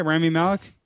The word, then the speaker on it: Rami Malek: Rami Malek? – Yeah, sure.